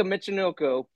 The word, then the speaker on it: Michinoku: I'm probably gonna butcher his last Michinoshu. Name. Yeah, Taka Michinoku.